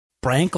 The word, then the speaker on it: Brank: Brank like bank. – I don't know. They said, let me look it up. I think it was a cuter tweet than that. Yeah, multiple people on Twitter said it rhymes with bank.